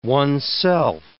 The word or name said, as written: oneself